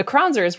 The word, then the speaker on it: Kronzer: The Kronzers were originally the Grunzers from Germany, but someone at Ellis Island couldn't spell that, so here we are. Nicole Kronzer rhymes with bronzer.